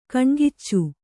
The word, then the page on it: ಕಣ್ಗಿಚ್ಚು: Kannada